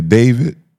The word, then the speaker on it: D4VD: The singer David. – Mm-hmm. – That's how you say his name is what? D4VD? – Mm-hmm.